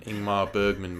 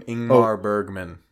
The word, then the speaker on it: Ingmar Bergman: Is that how you say his name? Ingmar Bergman. – Ingmar Bergman. – Yes. like Iceberg.